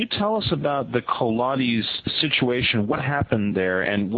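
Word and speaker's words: Colares: AJ, recently I've been reading a lot about a situation in an island, and correct my pronunciation of this, Colades. – That's perfect. Colades, that's the name of the island.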